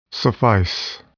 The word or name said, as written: suffice